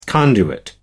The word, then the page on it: conduit: American English